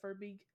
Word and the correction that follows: Verbeeg: Bugbear? Yes. – No, it's VERBEEG.